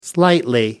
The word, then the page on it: slightly: American English